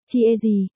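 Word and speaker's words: chia gì (茄子): Tiếng Trung, cà tím, đọc là, chia gì, khi phát âm từ này thì trông chúng ta như đang cười.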